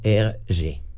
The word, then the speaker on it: Hergé: Su nombre real era Georges Prosper Remy y el seudónimo surgió de la inversión de sus iniciales RG, pronunciadas en francés como Hergé, ¿no? – Sí.